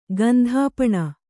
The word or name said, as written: ಗಂಧಾಪಣ